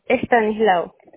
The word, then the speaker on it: Estanislao: Estanislao.